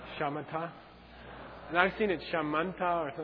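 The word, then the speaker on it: śamatha: In Sanskrit, the Shine is called Shamanta. – Sanskrit has a aspirated dental and an unaspirated dental. So in transcription, you have to write T or TH.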